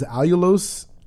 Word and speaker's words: allulose: She was, or I guess still technically is, a scientist by trade who uses rare sweeteners such as allulose.